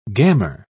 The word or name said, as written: gammer